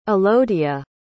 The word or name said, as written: Elodia